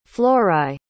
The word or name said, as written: florae